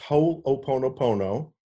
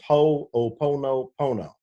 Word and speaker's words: Ho'oponopono: It's pronounced Ho'oponopono. – Okay. – and everybody stumbles over it. You're not alone. Everybody stumbles over it. You just pronounce every one of the, the syllables. So it's Ho'oponopono.